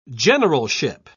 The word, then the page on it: generalship: British English